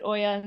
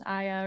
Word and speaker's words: Oia: And she was like, maybe it's a dialect thing. Maybe certain people in parts of Greece would pronounce it Oya and certain parts would pronounce Aya or Ia. – Maybe, yeah. – I always knew it as Ia, yeah. But who knows? So hopefully right. But it's O-I-A. – O-I-A.